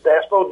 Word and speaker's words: DASPO: And Bob was a member of DASPO, or I guess you pronounce it DASPO, D-A-S-P-O, in Vietnam.